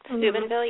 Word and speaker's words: Steubenville: And the most notable case, of course, the national case is, I don't know how to say it, is it Steubenville, Ohio? – Steubenville, yes.